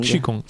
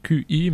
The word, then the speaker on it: Qigong: On va dire Qigong. – Qigong. – Voilà, ça s'écrit Q-I, mais ça se dit Qi.